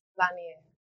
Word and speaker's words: LaNier: Lanier.